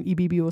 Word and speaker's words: Ibibio: Mich hat heute am meisten gekriegt, irgendwie ein Song zum Tanzen, golden, warm, klebrig, Honeybee von Ibibio Sound Machine.